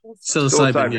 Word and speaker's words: psilocybin: The other thing is if you're going to be experimenting with psilocybin, so how do you say that? Psilocybin? – Psilocybin. Yeah.